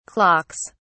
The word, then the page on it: clocks: British English